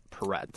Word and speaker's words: Perrette: Number 10 is Pauly Perretti. Is that how it's pronounced? – I think it's just Perrette.